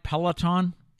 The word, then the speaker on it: Peloton: Our final story today is about the popular, am I saying it right, Peloton? – Yes.